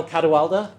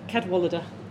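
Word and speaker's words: Cadwalladr: So immediate left, we have Carol Cadwalader. Is that correct? – Ish. – How should I say it? – Cadwalader. – Wow. Yeah. – You're close. You're in the ballpark.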